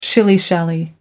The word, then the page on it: shilly shally: American English